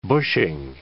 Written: bushing

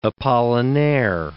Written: Apollinaire

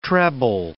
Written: treble

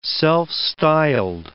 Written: self-styled

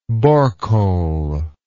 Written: borecole

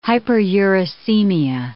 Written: hyperuricemia

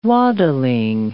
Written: waddling

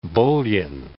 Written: bullion

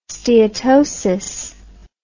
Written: steatosis

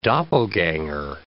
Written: doppelganger

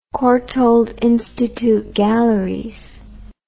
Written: Courtauld Institute Galleries

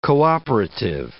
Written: cooperative

